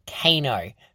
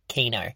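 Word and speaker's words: KEiiNO: We finished up a really great interview with Norway's Eurovision entry 2019, Kano, who repeatedly told us it is not pronounced Kino.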